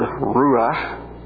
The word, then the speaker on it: Ruach: That is the Hebrew word Ruach. And you'll want to clear your throat at the end of that word as you pronounce it. Ruach.